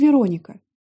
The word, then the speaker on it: Вероника: «Меня зовут Вероника, Ударение на второй слог.